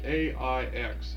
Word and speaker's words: Aix: I guess it's south of here, with this place called AIX. How's that pronounced? – Aix. – Aix? – Aix.